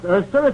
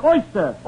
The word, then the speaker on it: oyster: It's not pronounced Erster, it's oyster. Oi, oi.